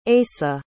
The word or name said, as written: Eisa